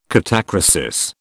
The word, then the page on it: catachresis: British English